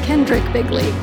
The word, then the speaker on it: Kendrick Bigley: Is that really how you say your last name, Bigley? – Bigley. It's actually Kendrick Bigley.